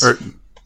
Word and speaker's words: Le Mans: but it's the 24-hour Le Mans. – Yep, 24 hours tournament. Le Mans.